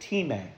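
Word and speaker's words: time (τιμή): It is a Greek word called time. That's how you would pronounce it, time.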